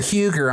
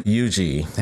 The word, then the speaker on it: Huger: Every time I hear Huger, I'm like, no, No. – Yuji.